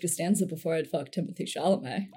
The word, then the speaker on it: Chalamet: And Bailey said, I would fuck George Costanza before I'd fuck Timothy Chalamet. I don't know if I said that right. – Oh yeah, Chalamet.